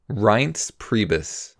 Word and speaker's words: Reince Priebus: Reince Priebus.